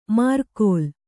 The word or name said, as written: ಮಾರ್ಕೋಲ್